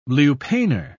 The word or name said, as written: lupanar